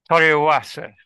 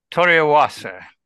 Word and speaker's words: Toriawase: Toriawase, I think, is how they pronounce it.